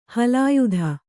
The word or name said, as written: ಹಲಾಯುಧ